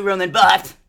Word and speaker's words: Roland Barthes: Thank you, Roland Barthes.